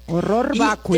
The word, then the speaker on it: horror vacui: Horror vacui, horror así como horror, y vacui, B chica A B-A-C-U-I, Bacui. – Horror Bacui. – Te voy a decir que horror Bacui. El horror Bacui, o sea, pero se pronuncia horror porque está en latín.